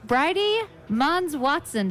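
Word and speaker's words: Bridie Monds-Watson: I have Bridie Mons Watson. Did I pronounce that correctly? – Yeah, you did.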